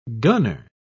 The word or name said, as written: gunner